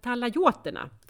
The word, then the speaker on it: talajoterna: Han är nämligen intresserad av talaoiterna. talajoterna, så uttalas det.